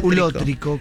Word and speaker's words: ulótrico: Ulótrico. – ¿Qué es ulótrico? Con acento en la O.